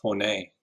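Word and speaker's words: honing: It's not honey, it's honey.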